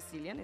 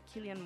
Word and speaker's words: Cillian: Está por ahí el, el Killian Murphy, que no se dice Cillian, es Killian Murphy.